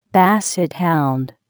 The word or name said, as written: basset hound